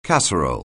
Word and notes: casserole: anglais

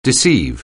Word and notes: deceive: anglais

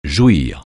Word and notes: jouir: francais

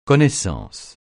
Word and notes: connaissance: francais

